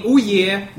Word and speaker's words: Ouya: It is spelled O-U-Y-A, so I'm going to call it the Ouya.